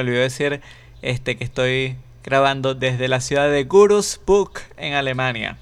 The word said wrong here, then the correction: Würzburg: Pues no, es Würzburg y es pegado. Würzburg se escribe W U con dos punticos, o sea, con la diéresis, R, Z, B, U, R, G, Würzburg. Repeat after me, Würzburg.